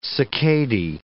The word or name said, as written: cicadae